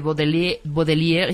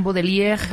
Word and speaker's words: Baudelaire: Yo creo que los que sean así como franceses y que tengan... – Dirán Baudelaire, pero yo, como soy de Chiapas, digo Baudelaire.